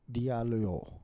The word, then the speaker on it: Diallo: A-M-I-N-A-T-E space D-A-L-I-O, if I'm not wrong. Or D-A-L-L-I-O. – Aminate Diablo. – Diallo. – Diallo, Diallo. D-I-A-L-L-O.